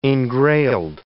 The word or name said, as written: engrailed